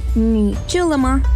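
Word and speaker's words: ni chulama (你吃了吗): Mientras en Occidente una persona puede decir te amo, en China algunos padres y parejas simplemente preguntan ¿ya comiste? Que se pronuncia ni chulama.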